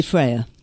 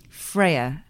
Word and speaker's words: Freya: Say Freya. – Freya. – Mm. – So almost like there's a frayed thread, Freya.